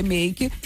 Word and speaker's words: Make: Se escreve Catita Make, mas se lê Make.